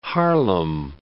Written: Haarlem

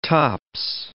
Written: tops

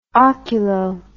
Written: oculo

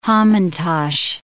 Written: hamantasch

